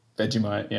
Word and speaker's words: Vegemite: Well, firstly, you've done very well to pronounce it the first time. – Thank you. – Vegemite, I might say. But yeah, I've do you say Vegemite? – Vegemite, yeah, that's right.